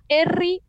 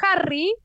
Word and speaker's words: Erri: es Erri, que en realidad era Harry, pero Ah. – un día alguien le dijo Erri y él lo adoptó.